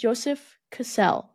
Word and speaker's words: Joseph Cassell: Learned how to pronounce his name, Joseph Cassell. You heard it here first on Evolution of Snake. It is pronounced Joseph Cassell.